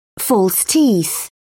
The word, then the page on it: false teeth: British English